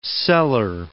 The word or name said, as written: seller